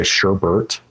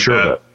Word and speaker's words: sherbet: One extra letter one that does bug me, though, is when people say sherbert. – Oh, yeah. – It is like sherbet.